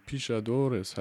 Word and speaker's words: Pixadores: Ähm, Pichadores heißt der, wenn ich das richtig ausspreche.